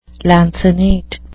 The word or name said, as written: lancinate